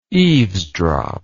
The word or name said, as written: eavesdropper